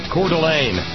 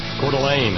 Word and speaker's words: Coeur d'Alene: Well, there's my first pronunciation screw-up of the evening. Thanks, Jan. Coeur d'Alene. Coeur d'Alene, Idaho.